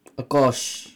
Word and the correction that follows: Aqsah: Akash. – No, Aksa.